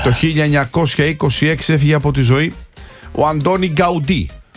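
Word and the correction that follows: Gaudí: Σωστή προφορά είναι Γκαουδί, σημαντικός Καταλανός αρχιτέκτον του μοντερνισμού.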